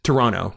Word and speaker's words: Toronto: That's how it's correctly pronounced. – Toronto. – No T.